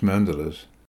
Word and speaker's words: mandala: It is common in Tibetan Buddhist mandalas. Note that in the Sanskrit word mandala, the accent is on the first syllable.